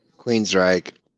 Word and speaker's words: Queensrÿche: because he's the singer from Queensrÿche. Queensrÿche. Am I saying that right? – Queensrÿche. – Queensrÿche. Yeah. See, I knew I was saying it right.